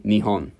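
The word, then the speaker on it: Nihon (日本): Nihon.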